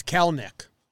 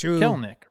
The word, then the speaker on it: Kelenic: Kelenic. What do I keep saying? – It's Kelnick. – Kelnick. – Two syllables. – Ignore that second E when you're talking about him.